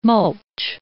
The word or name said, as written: mulch